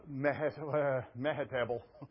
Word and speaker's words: Mehetabel: Now, when I went to the house of Shemaiah, the son of Deliah, son of Mehetabel, it rhymes with vegetable, awesome, who was confined to his home, he said, and why was he confined to his home?